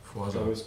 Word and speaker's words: Forza: Ja, heißt das eigentlich wirklich Forza oder ist es einfach nur dumm, wie wir es aussprechen? Heißt es Forza? – Forza, Forza Horizon.